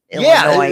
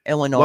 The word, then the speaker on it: Illinois: Two suburban officers in Illinois, or those of you that seem to need to pronounce the S, Illinois. – Yeah, don't get like. – That makes me nuts when they do that. Illinois.